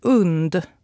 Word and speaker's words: UND: Vi på radion har blivit informerade om att det ska uttalas UND. Tänk hund minus H.